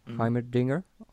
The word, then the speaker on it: Heimerdinger: Dann entlassen die Mike Maccagnan und sein Right-Hand Man Brian Heimerdinger. Kann man schön auf Deutsch aussprechen. Heimerdinger auf Englisch.